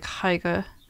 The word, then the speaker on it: Haigha: It looks like Haigha he pronounced it so as to rhyme with mayor, so hair.